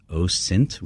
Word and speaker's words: OSINT: It's OSINT with a soft S.